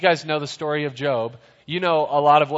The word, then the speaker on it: Job: It's spelled like Job, but it's Job.